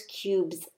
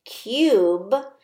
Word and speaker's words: cube: Cube has the long U and it makes this sound. U. Cube. Cube. It might sound like we're saying Q, but I promise you we're not. We're saying cube.